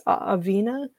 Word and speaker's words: Aaveena: I don't know how to say this person's handle at all, but it's spelled AAVEENA, which I think is Avena.